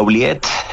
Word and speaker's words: oubliette: Hay un lugar que se llamaba La Ubiet. – Sí. – No sé cómo se pronuncia, si se pronuncia así. – Sí, la oubliette. Es un poco del término francés, como de oublier, como de olvidar, ¿no? – Olvidar.